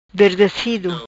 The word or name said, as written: Derdezido